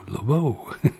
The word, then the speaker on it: Lobo: And we started off with Romper La Imagine by Bards and Lobo, and there's an accent on the second O, Lobo. Okay. And on we go with Boxy, B-A-I-X-I, Boxy.